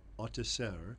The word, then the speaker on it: Autesserre: I have an assistant professor of political science at Barnard College, whose name is Severine Autesserre, who, and I'm going to ask her to pronounce it as well.